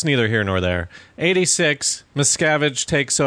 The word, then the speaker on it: Miscavige: And that's when, how do you say it? Miscavige? – Miscavige. – Miscavige? – Is it? I thought it was like Miscavige or something. – But no, it's Miscavige.